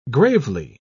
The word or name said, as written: gravely